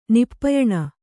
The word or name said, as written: ನಿಪ್ಪಯಣ